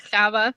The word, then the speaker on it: Chava: My name is Chava. If you want to pronounce it the right way, then you get a little sa and you go like Chava.